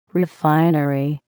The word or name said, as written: refinery